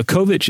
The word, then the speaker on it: Kovic: This is K-O-V-I-C with the last part having a C-H pronunciation like in chocolate. So Kovacs